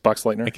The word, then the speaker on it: Boxleitner: And uh, your buddy from Tron, Bruce uh, Boxleitner, am I pronouncing it correctly? Boxleitner, yeah.